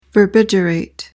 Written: verbigerate

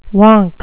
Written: wonk

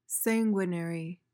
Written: sanguinary

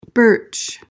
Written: birch

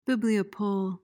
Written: bibliopole

